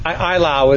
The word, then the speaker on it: Eylau: Is that how you pronounce it, Eylau? – Eylau is the way I pronounce it.